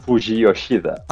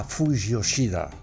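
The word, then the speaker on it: Fujiyoshida: És que em costa tant de pronunciar-ho que es diu Fuyoshida. Ho he dit bé ara, o no? – Sí, Fuyoshida. – Ah, Fuyoshida.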